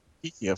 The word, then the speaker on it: Geeke: Geeky, of course. – And that's spelled G-E-E-K-E. Get it? Yes. Geeky. As in your geeky person.